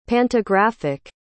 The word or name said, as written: pantographic